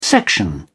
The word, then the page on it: section: British English